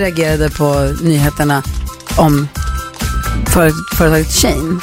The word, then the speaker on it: Shein: Det heter Shein. – Shein. – Som she och in. Shein.